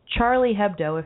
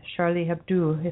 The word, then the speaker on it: Charlie Hebdo: The offices of the French satirical magazine Charlie Hebdo, if you're just pronouncing it in the English way, and Charlie Hebdo if you're the French, which is really a bad pronunciation.